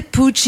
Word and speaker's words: Puig: Ça s'écrit P-U-I-G, mais ça se prononce Puig.